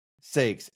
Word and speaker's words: Saeks: It's just Ford Saeks, S-A-E-K-S.